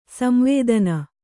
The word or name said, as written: ಸಂವೇದನ